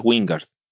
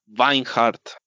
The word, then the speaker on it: Wijngaard: Beto, encontré la pronunciación de Wingard y no es Wingard, pero no la puedo pronunciar. – Sí, es. – Mira, suena Vinehart.